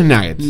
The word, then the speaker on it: nuggets: La pronunciación es nuggets.